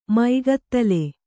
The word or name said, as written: ಮೈಗತ್ತಲೆ